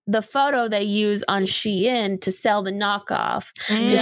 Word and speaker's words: Shein: Is Shein. It is not Sheen.